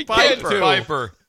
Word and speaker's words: Pieper: There's nobody has a buddy that his name is Peeper. – Oh, P-I-E-P-E-R. Can be pronounced that. – No, it can't. No, it's Piper. How would you ever get Peeper from that? – Aaron Peeper.